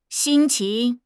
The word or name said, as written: xin1 qing2